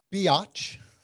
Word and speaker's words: Biatch: You do a stand-up routine that you call Confessions of a Prairie Biatch. – Yeah, rhymes with witch.